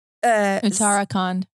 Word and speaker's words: Uttarakhand: Uttarakhand. – Sotman told me how to say this properly. – You got it. – He said, I'm not putting enough emphasis on the tar.